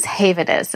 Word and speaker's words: HAVENOUS: HAVENOUS, and that's spelled HAVENOUS. And I was pronouncing it wrong for months. So we're going to try to get it together today. But it is HAVENOUS.